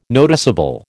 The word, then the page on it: noticeable: American English